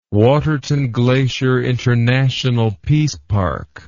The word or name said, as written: waterton glacier international peace park